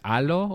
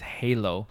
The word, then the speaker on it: Halo: Her name is Juana Molina, and the album is called, if you pronounce it in Spanish, is Halo, or in English is Halo.